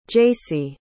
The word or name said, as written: jasey